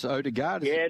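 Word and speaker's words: Ødegaard: Is that how you pronounce Odegaard? – Spot on. You've been listening to Belly. Uh-huh. You're a perfect pronunciation.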